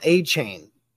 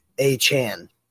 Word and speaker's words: Achane: Did you see it's not Devon Achane? He said in his interview that it is, he does not want it to be pronounced like that. It's Achane.